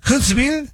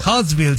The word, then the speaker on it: Huntsville: Huntsville, City. ¿Sí lo dije bien, o no? – Sí. Huntsville. – Huntsville, City.